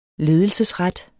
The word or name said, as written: ledelsesret